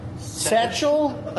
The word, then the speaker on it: satchel: Satchel. – A satchel. – Satchel. – A satchel. – You pronounce the T. Satchel.